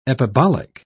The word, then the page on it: epibolic: British English